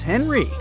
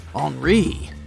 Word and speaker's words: Henri: And this is Henry, but it's more correct to pronounce his name as Henri, since he's French.